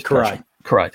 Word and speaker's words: Karai: And then, and of course he gets into the fight with, Kyra. Is that how you pronounce it? – Or it was Karai. Thank you. I was pronouncing that wrong.